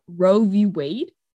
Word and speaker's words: Roe v. Wade: Okay, sure, you can be the voice for the voiceless, but like hello, I don't think that's now that Roe v. Wade? – No, you're not saying it wrong.